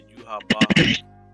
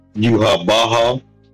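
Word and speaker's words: Yhwach: no, he was he was felled in battle by Yuha Baha. That that's how you pronounce it apparently. – Yuha Baha.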